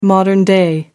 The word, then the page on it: modern-day: American English